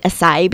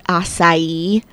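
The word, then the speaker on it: açaí: I was calling it acai berries, and I was talking with my sister on the phone, and she stopped me right away, and she goes, "Homeschool, you know that it is acai."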